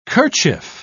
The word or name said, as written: kerchief